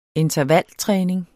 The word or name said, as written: intervaltræning